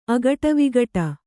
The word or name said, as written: ಅಗಟವಿಗಟ